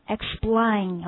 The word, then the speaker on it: explain: Explain, OK? 好，首先大家注意一下这个发音的问题啊，也是重音在第二个音节上。